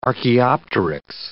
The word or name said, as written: archaeopteryx